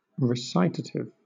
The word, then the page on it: recitative: British English; adjective